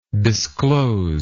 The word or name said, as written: discloser